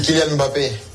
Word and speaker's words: Kylian Mbappé: C'est Kylian Mbappé. Kylian Mbappé. Il n'y a pas d'apostrophe.